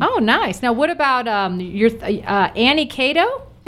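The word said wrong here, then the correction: Annie Karto: Annie Carto. – I said that wrong.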